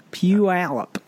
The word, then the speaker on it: Puyallup: Puyallup.